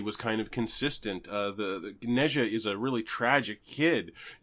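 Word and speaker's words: Nezha: It's called Neja, but it's spelled N-E-Z-H-A. N-E space Z-H-A.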